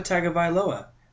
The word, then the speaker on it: Tagovailoa: Tagovailoa. – Viola? Like the violin? Like a bigger violin? Nah, it's Tua Tagovailoa, the dude who's going to win the Heisman next year.